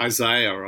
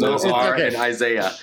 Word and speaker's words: Isaiah: So this is from Isaiah, or Isaiah, I think you Americans say, but anyway, the correct pronunciation is Isaiah.